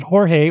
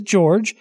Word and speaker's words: Jorge: Okay, well, George's name is spelled like yours. He doesn't pronounce it Jorge or Jorge. He pronounces it George.